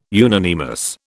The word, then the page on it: unanimous: British English